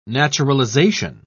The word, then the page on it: naturalisation: British English